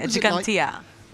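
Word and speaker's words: Ġgantija: And talking about Gozo, I think you performed once open air at the Jantija Archaeological Park. I hope I got the name right. – Yeah, Gigantia.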